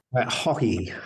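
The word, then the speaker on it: Haughey: Hawhey.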